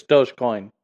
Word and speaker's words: Dogecoin: And it's spelled doggy coin, but it's Dogecoin.